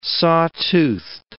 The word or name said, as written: saw-toothed